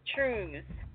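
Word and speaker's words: Truez: I'm gonna go to Trues.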